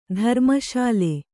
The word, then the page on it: ಧರ್ಮಶಾಲೆ: Kannada